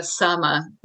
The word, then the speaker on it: Saama: Is it Saama or like? – So, yeah, Saama, double Saama. A.